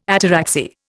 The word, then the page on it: ataraxy: Indian